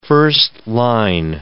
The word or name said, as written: first-line